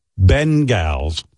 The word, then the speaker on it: Bengals: The official pronunciation is, Robin's right, Bengals, like Ben Stern, Bengals, Bengals. So from now on, everyone will say the Cincinnati Bengals.